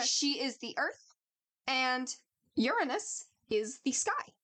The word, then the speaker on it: Uranus: So he can also be, his name can also be pronounced Uranus. Pause for the giggle of the listeners. and us, apparently. Or Uranos. And this is when it's spelled with an O at the beginning.